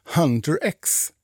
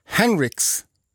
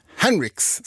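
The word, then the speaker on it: HUNTR/X: Jag sa ju att det här var Golden med Hunter X. Nej, så ska man inte uttala det där namnet, har jag lärt mig nu. Utan det här är ju, det ska vara Henrix ska det uttalas.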